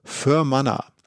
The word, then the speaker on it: Fermanagh: Ich komme jetzt mal auf die Gegend, in die ich zuerst gefahren bin, die ich relativ bewusst gefahren bin, die heißt Fermanagh. Also das wird geschrieben F-E-R-M-A-N-A-G-H, Fermanagh geschrieben, Fermanagh, also gut aussprechen kann man es nicht, deswegen bin ich auch hingefahren.